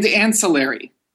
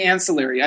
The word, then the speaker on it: ancillary: And ancillary. We Americans say ancillary. Are you a Canadian? Are you a subject of the crown that you would say ancillary? – I prefer highway robbery.